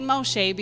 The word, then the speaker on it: Moshe: And I say Moshe because that's how you say his name in Hebrew,